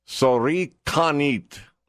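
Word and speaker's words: kalsarikännit: There is a Finnish word pronounced sorikanit.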